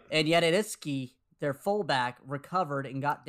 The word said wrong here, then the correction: Juszczyk: It's Juszczyk. – Oh, Juszczyk. Whatever.